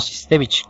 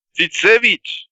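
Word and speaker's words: Sytsevich: Sí, es Sitsevich, no, no Sistevich, como dijiste la primera vez. – ¡Sid Sage!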